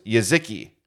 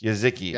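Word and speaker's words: Jeżyki: Jezki, J-E-Z-Y-K-I, Dark Cherry. We looked up how to pronounce this before the show, and it's Jezki, right? That's what we determined? – Yes. – Jezki.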